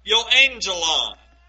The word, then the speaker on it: euangelion: Well, if you look at the Greek word, in the Greek, when you transliterate that Greek word, it is pronounced something like euangelion.